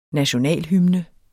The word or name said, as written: nationalhymne